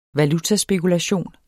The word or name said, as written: valutaspekulation